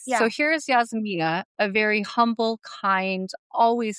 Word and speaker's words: Yasmina: So Yasmina. Yeah. Did I say it right? – You said it right.